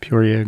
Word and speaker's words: Peoria: Is that how you say it? – Peoria.